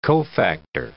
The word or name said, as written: cofactor